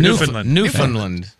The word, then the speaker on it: Newfoundland: Newfoundland.